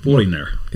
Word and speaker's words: Foreigner: Foreigner.